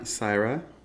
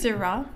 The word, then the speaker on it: Syrah: So, it has 25% Syrah, if I'm saying that right. – Syrah? – Yeah, there you go.